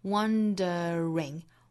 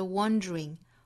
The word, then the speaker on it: wondering: 第二句 wondering 这个词呢，可以读成三个音节的 wondering wondering，也可以读成两个音节的 wondering wondering，也就是中间有一个刷项是可以退位不要的，可以读成三个音节，也可以读成两个音节，再跟后面的 if 做连读。